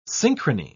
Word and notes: synchrony: British English

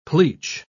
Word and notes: pleach: British English

